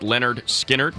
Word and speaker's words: Lynyrd Skynyrd: Debut album, pronounced Lynyrd Skynyrd.